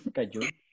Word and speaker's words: kaiju: Es un kaiju, si se dice así, ¿no? – Sí, kaiju.